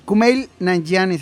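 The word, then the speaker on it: Kumail Nanjiani: Mercury, a Voldemort también, eh, Kumail Kumail, Nanjiani se pronuncia.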